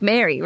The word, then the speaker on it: Mare: So not Mare. – Well, I mean, it's like Mary, right? Mare. – But is it spelled like M-A-R-E? – Yeah, M-A-R-E.